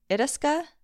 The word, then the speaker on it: Itasca: Itasca State Park? – Yeah. – Yeah. – Sounds right.